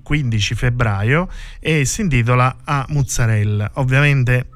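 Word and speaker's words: 'A mozzarella: La, la, lingua napoletana prevede la A alla fine della parola mozzarella, invece noi l'abbiamo abbreviata affinché dovunque si pronunciasse così come si pronuncia a Napoli, con, appunto con la, eh, la A troncata quindi A mozzarella è inequivocabile, la pronunceranno tutti benissimo.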